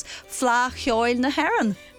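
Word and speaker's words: Fleadh Cheoil na hÉireann: We're meeting all sorts of people who are gearing up to take part in this year's Fla Chioil na Héireann. – You are getting better and better at pronouncing that.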